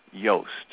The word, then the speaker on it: Joost: By the fence on my right, a little ahead of me, is my elder brother, Joost, pronounced Yoast. So it's spelled J-O-O-S-T, but I need to remember to pronounce it Y-O-A-S-T, Yoast.